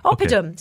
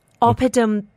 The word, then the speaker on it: Oppidum: Okay, so I saw a story that suggests that a company called Apodum. – Opidum. – Sorry, there are too many syllables. – Sounds like a medicine. – It sounds like a medicine. And the next word is bunkers. oppidum bunkers.